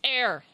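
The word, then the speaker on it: Éire: Okay, do you know how to say Ireland in Gaelic? – No. – It's air. Do you know how that's spelled? E I R E.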